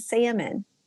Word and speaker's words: salmon: I think the S is silent like the L in salmon.